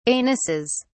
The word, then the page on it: anuses: British English